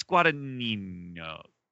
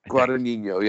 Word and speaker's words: Guadagnino: I think it's Guadagnino. Guadagnino. – Guadagnino, yeah. I feel like the GN in there back-to-back has the nyang-nyang to it, but I don't know.